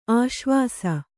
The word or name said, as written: ಆಶ್ವಾಸ